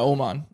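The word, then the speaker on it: Åman: Well, that's how someone told me how to pronounce it, you were saying Omar, but Oman. – Yeah, Oman.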